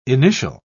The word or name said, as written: initial